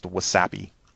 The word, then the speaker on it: WASAPI: Around here, it's pronounced WASAPI.